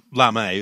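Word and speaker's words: Lamé: It's spelled Gold Lame. – Well, Lame. It's got the little not the accent over the E.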